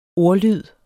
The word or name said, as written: ordlyd